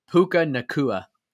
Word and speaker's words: Puka Nacua: Puka Natau. How do you say it? – Puka Nacua.